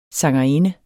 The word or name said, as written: sangerinde